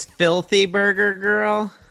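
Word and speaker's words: Flty Brgr Grl: And then we heard before that, tell me if I'm pronouncing this correctly, Faulty Burger Girl. – So I think it's Filthy Burger Girl. – Ah, okay. – So they've removed all the vowels.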